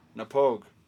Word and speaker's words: Knappogue: It's spelled K-N-A-P-P-O-G-U-E. – Oh, Napogue. – Napogue.